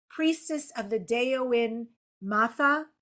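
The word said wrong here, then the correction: Daoine Maithe: Is that correct? – Dini Maha. It's Irish. And I know Irish is very different for English speakers to look at, because it has different grammar and pronunciation rules, but it's Dini, which means people, and then Maha, which means good.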